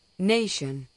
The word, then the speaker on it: nation: Nation, nación. Temptation, tentación. Duration, duración. Admiration, admiración. – Observe que las palabras que terminan en shun se acentúan en la sílaba anterior a shun.